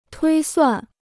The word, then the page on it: 推算: tuī suàn